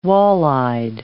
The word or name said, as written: walleyed